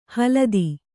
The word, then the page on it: ಹಲದಿ: haladi